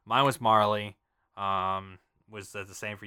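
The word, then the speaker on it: Marle: Oh, apparently in Japan, her name is M-A-R-L, so it's supposed to be Marl.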